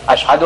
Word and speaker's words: اشہد: سے شین نہیں کہا جاتا تھا, اشہد ان لا الہ الا اللہ کو اسد ان لا الہ الا اللہ کہتا تھا. کیا رسول اللہ نہیں جانتے تھے کہ اس کا تلفظ صحیح نہیں ہے, یہ حبش کا رہنے والا ہے, عرب کے درمیان عربی زبان میں اذان دلوانا.